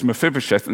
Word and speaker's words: Mephibosheth: There's Mephibosheth, and then there's Hanun.